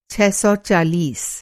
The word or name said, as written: छह सौ चालीस